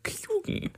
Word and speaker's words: Keoghan: Habe ich nämlich herausgefunden, dass er Keoghan ausgesprochen wird.